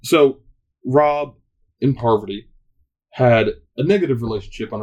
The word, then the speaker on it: Parvati: You say her name? – Parvati. Sorry, Freddie. Sorry. – Freddie? – Freddie was the one who called me out. He sent me a phonetic email of how to pronounce her name.